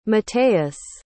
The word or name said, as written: matheus